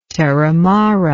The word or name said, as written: terramara